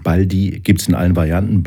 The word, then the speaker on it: Baldi: Ähm, dass du nämlich gar nicht Baldi heißt, sondern Baldi, also diese Betonung auf dem I hinten raus und wir das eigentlich seit 25 Jahren falsch sagen, stimmt das? Ist Quatsch. Ist Quatsch.